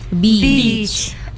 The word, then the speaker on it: beach: So we pronounce it as uh-huh. beach. – Okay. So it means like double E ya, kayak double E ya, beach.